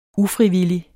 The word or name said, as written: ufrivillig